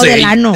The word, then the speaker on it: Delano: Pero se dice Delano, ¿no? – Ah, yo no sé. – No, Delano.